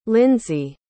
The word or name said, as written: linzy